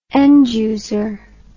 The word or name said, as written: end user